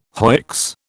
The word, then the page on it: hoicks: British English